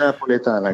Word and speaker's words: Napoletana: Okay, und bei Ihnen gibt es ja auch die ganz echte Pizza Napoletana, heißt sie so? Habe ich richtig ausgesprochen? – Ganz echte Pizza Napoletana, genau.